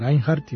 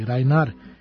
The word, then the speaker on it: Reinhardt: Rođen u romskom kampu u Belgiji, Django Reinhardt ili Rainer, kako se još izgovara njegovo prezime,